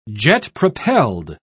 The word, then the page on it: jet-propelled: British English